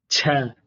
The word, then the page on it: ch: Sanskrit